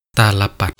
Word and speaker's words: ตาลปัตร: สวัสดีครับวันนี้ก็พบกับรายการคำวัดวันนี้เสนอคำว่าตาลปัดคำว่าตาลปัดสะกดด้วยต่อเต่าสระอาลอลิงปอปลาแมนากาศต่อเต่ารอเรือตาลปัด